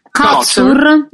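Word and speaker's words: Kotsur: Cotsur.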